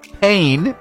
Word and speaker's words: pain: Again, the word is pain, P-A-I-N. In French, it's pronounced pain.